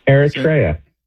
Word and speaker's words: Eritrea: Well, there's an African country just north of Ethiopia, and it's spelled E-R-I-T-R-E-A. – Eritrea. Yeah. – How would you say that? – Eritrea. – Eritrea. Thank you.